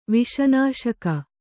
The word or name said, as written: ವಿಷನಾಶಕ